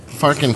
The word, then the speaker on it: Farkham: Is that how you say it? – Farkham. – Just Farkham.